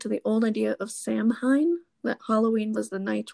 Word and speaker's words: Samhain: That word is pronounced Samhain.